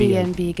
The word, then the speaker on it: Airbnb: Airbnb, genau. Und zwar das heißt, das R spricht man Deutsch und die Bs spricht man amerikanisches Englisch.